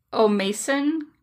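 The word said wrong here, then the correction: Ó Maicín: versus Ireland? – Oh, O'Mochin versus Ireland.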